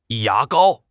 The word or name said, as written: ya2 gao1